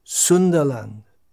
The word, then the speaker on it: Sunderland: It's spelt S-U-N-D-E-R, and we have Sundara, if that's rendered in English, as S-U-N-D-A-R, Sundara. An English word is Sunda. There is a town in northeast England called Sunderland, and interestingly, in Sunderland itself, they pronounce it Sundaland, Sundalan, for all it's worth.